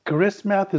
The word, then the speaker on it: Karismath: Karismath. Am I pronouncing it right? – Yes. Karismath is the name of my program.